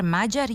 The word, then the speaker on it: Mađar: Tako bi prezime Mađar trebalo da se akcentuje u srpskom jeziku, isto kao i naziv za žitelja Mađarske. Dakle, kratkouzlazni akcenat na prvom slogu i dužina na sledećem.